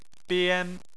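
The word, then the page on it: bian: tone 1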